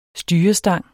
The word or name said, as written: styrestang